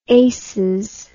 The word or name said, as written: aces